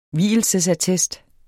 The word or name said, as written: vielsesattest